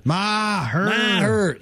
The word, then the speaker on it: Maher: And Mayer He gets on Maher. What do they say his name again? – Maher.